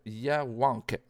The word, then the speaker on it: Yawank: C'est comme ça qu'on prononce ça? Yawank, c'est ça.